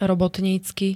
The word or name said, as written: robotnicky